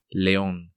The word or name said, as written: Leon